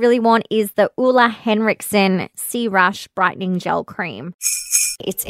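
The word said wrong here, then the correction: crème: Um, I'm editing this episode right now, and I actually think it's pronounced creme, not cream.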